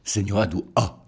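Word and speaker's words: Senhora do Ó: Alors on vient d'entendre dans cet extrait euh, le nom euh, Senora do O. Est-ce que je le dis bien Senora do O.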